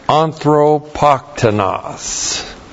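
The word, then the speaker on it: Anthropoktonos (ἀνθρωποκτόνος): And then we have anthropoktonos. Barely got that on there. A-N-T-H-R long O P-O-K-T-O-N-O-S. Anthropoktonos.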